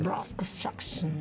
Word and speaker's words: Brass Construction: Brass Construction, yeah. – La pronuncia è andata bene. – Perfetta, dopo ti dovrò raccontare queste versioni.